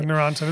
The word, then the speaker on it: Neurontin: Yeah, Neurontin.